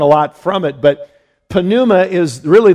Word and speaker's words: pneuma: And that is an interesting, that's the word pneuma. You might say, well, isn't, wouldn't that be pronounced pneuma? There's no such thing in Greek as a silent letter.